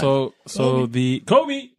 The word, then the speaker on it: Kobe: You didn't say it right. Try again. – Sorry, Kobe. Wait. Kobe 5 ProTro Final Championship. – No, I meant just the first word. – What? – Kobe. Just like that. That's how you have to say it.